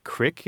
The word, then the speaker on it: creek: You know, in the northern part of the Midwest and even as far west as the Northwest in parts of California, crick is pretty well documented as being one of the pronunciations of C-R-E-E-K.